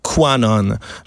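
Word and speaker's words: QAnon: Euh, c'est la théorie qu'on va appeler Q ou Quanon. J'espère que je le prononce bien, c'est Q-U majuscule A-N-O-N, Quanon,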